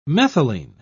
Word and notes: methylene: British English

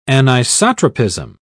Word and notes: anisotropism: British English

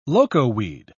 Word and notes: locoweed: British English

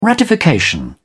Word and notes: ratification: British English

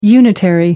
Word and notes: unitary: American English

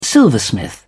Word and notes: silversmith: British English

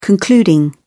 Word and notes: concluding: British English

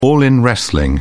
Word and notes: all in wrestling: British English

